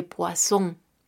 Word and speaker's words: poisson: La por el artículo, el sustantivo se escribe P-H-O-T-O-G-R-A-P-H-I. E, la fotografía, la fotografía, palabra transparente. Enseguida, cuando encontremos una vocal doble S y vocal, estas S, aunque sean dobles, las pronunciaremos como una S normal en español. Un ejemplo claro sería le poisson.